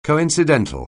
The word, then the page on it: coincidental: anglais